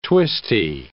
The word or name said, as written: twisty